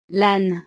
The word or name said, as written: l'ane